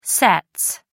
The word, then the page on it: sets: American English